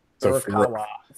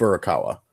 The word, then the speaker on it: Furukawa: And I always get his last name wrong. It's Furukawa. Help me out here. Is that I always mispronounce it? – I think it's like Furukawa. – I have to like contort my face to say it just right. – I think it's Furukawa.